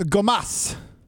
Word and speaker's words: Gomas: I mean, am I saying Gomas? I feel like I can't say that. How do I say it? – It's Gomas. – Is that right? That doesn't sound right. – Gomas. Just Gomas.